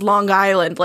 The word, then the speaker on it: Long Island: I think it's pronounced Long Island, like it's one word.